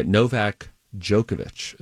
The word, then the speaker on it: Novak Djokovic: Uh, Novak Djokovic is how you pronounce that name.